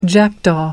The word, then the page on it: jackdaw: American English